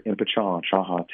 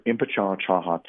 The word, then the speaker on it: Impichchaachaaha: Chukma, Soholst Chifuat, Jerod Impichchaachaaha Tate. Impichchaachaaha Chokat Michishawi Iksa, Ishtaun Chololili, Talo Ikbili. Hi, my name is Jerod Impichchaachaaha Tate. And that's how you pronounce that.